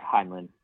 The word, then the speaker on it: Heinlein: Is that, did I say that right? – Yeah, Heinlein. I believe.